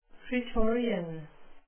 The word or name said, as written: praetorian